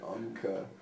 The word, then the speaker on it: Umcka: It's called UMCA. It's spelled UMCKA.